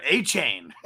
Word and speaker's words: Achane: But it's no, pronounced Devon A Chan.